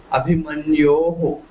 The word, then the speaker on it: अभिमन्योः: abhimanyoho, so the correct pronunciation for the word abhimanyu in Shastri Vibhakti would be abhimanyoho, okay.